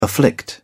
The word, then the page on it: afflict: British English